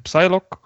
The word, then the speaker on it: Psylocke: Het gaat dus over Wolverine die achter Cyclops zijn rug een nieuw X-Force team opstart met onder andere Deadpool, Phantom X en Psylocke. Om bepaalde dreigingen te bevechten die een hardere aanpak nodig hebben. – It's Psylocke. Dus ja. The P is silent.